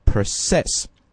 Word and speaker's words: process: 那么在这里呢，我们把它刚才读成 process, 这个地方是个动词，重音在第二个音节在 cess 上面，那么表示的是它的动词含义，就是处理啊，处理一些古巴政府的交易， process Cuban government transactions, 哎，交易跟前一句的那个 commercial dealings，哎，是基本上同意的替换。